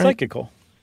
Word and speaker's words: Psychical: Eventually, they bring in a paranormal investigation team, the Society for Psychical, Physical, Psychical. – Yeah, the SPR. We've talked about them plenty of times. – Yeah. Am I saying that right? – Psychical.